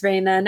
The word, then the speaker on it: Reina: It's Reina.